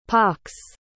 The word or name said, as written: pocks